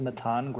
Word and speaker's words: Mattan: So my name is Matan Griffel. That's spelled M-A-T-T-A-N.